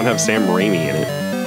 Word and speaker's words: Raimi: Sam Raimi, is that how you say that? – Yeah, Sam Raimi. – Ram-I? – It's pronounced Sam. – I'm never sure. Sounds weird. – What? – Sorry, keep going. – It's Raimi.